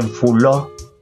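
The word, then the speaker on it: fulô: Así pasa también con la palabra flor en, Brasil, en esa región donde en lugar de decir flor, dicen fuló, pero no terminan de decir la palabra, la R final no la pronuncian, entonces queda fuló.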